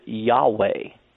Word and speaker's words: Yahweh: Now, we don't know exactly how to pronounce that word or that name of God, but in order to make an attempt of it, most people pronounce it this way. They pronounce it Yahweh.